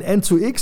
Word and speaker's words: EN2X: Bei EN2X muss man aber aussprechen N 2 X.